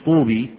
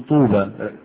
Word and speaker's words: طوبى: طوبى، لما تقولش طوبي تقول طوبى، رسمتها إيه؟ ياء ونطقتها إيه؟ ألف، والباء عليها إيه؟ فتح. طوبى باء باء باء. لكن الرسم في الكتابة في الإملاء ياء، والنطق أو هذه من الأشياء تنطق ألفا وترسم إيه؟ ياء.